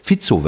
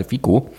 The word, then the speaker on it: Fico: Das hatte auch ein gewisser Herr Fizzo, wird da, glaube ich, ausgesprochen. Ne? Nicht ich dachte Fico. Fizzo, ich habe heute Morgen Nachrichten gehört, da haben sie ja über Fizzo gesagt. Ich sage jetzt auch mal Fizzo.